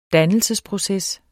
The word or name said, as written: dannelsesproces